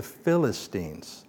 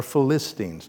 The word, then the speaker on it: Philistines: And they lived in the coastal areas, and they were known as the Philistines, or Philistines, depending on how you say it.